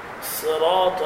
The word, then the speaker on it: صراط: بسم الله الرحمن الرحيم الحمد لله رب العالمين الرحمن الرحيم مالك يوم الدين إياك نعبد وإياك نستعين اهدنا الصراط المستقيم صراط الذين أنعمت عليهم غير المغضوب عليهم ولا الضالين. عند نطق الصاد هكذا تنطق هكذا صين صراط الذين أنعمت عليهم لا تقول صراط لا تبالغ في تفخيم الصاد